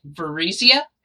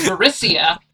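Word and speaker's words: Varisia: Up until I got hired at Paizo, I was pronouncing it Varizia and Varizian. You know, like Parisian and Paris. – Is that not correct? – That's not correct. You pronounce the S phonetically. So it's Varicia and Varicians.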